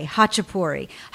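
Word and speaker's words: Khachapuri: You are trending, my friend, because I want to segue to Hachapuri. Is that the proper pronunciation? – Yes. – Okay, Hachapuri.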